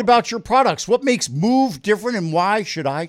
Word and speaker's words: Muv: It's Move, M-U-V.